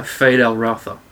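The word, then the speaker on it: Feyd-Rautha: Fade Al-Ratha.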